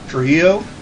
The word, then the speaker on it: Trujillo: Tru- How do you say it? Trujillo.